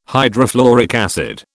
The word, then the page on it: hydrofluoric acid: British English